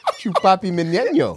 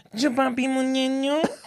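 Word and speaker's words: Chupapi Muñaño: Chupapi Mignenio. Chupapi Mignenio. Chupapi. You have to say it like that. Chupapi Mignenio.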